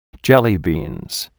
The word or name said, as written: jellybeans